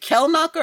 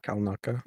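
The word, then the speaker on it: Kelnacca: I thought they did a good job with is it Kelnaka or Kalnaka? – Kalnaka. – Yes, Kalnaka. Kalnaka. It's spelled like Kel, but I think it's Kal.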